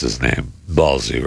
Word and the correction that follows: Balassi: right? – It's Balassi. – Yeah. – Balassi? – Balassi. – That's what we're saying? – Mm-hmm. – That's what we're saying, yes. – Balassi? – Mm-hmm.